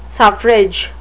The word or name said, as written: suffrage